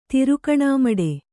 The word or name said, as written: ತಿರುಗಣಿಮಡು